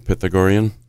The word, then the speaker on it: Pythagorean: That is the correct pronunciation, right? Pythagorean? – Yes, I've heard it other ways, but whichever works best, it depends where you come from.